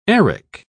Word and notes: arak: British English

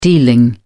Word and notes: dealing: British English

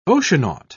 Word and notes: oceanaut: British English

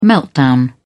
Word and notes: meltdown: British English

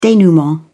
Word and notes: denouement: British English

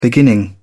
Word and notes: beginning: British English

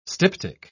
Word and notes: styptic: British English

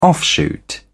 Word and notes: offshoot: British English

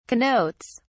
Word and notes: connotes: British English

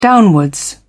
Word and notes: downwards: British English

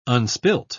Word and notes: unspilt: British English

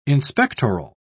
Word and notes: inspectoral: British English

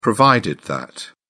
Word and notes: provided that: British English

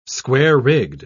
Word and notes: square-rigged: British English

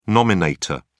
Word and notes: nominator: British English

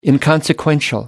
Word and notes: inconsequential: American English

